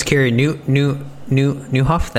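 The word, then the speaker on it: Carey Nieuwhof: One of my favorites, Kerry Newhoff.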